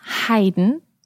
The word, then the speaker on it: Haydn: Let's just run through the correct German pronunciation of those composers' names again. Johann Sebastian Bach, Georg Friedrich Händel, Richard Wagner, Robert Schumann, Joseph Haydn and Wolfgang Amadeus Mozart.